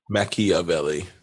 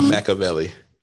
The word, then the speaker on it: Machiavelli: He pronounced it correctly. He wasn't actually talking about Pac. – Machiavelli. Mm-hmm. Not Machiavelli.